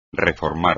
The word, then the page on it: reformar: espagnol